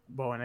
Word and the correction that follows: Baron Cohen: Baron Cohen.